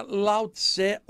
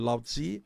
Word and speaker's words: Lao Tzu: Eu aprendi que não era Lao Tse ou Lao Tzu, era Lao Tzu.